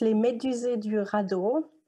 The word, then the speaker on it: Les Médusés du radeau: So it's Les Médusés du radeau, and it's a French theater ensemble.